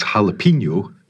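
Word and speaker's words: jalapeño: And also, it's jalapeno, but it's habanero, because we don't have that little squiggly line above the N. Not because of that. That represents how it is pronounced.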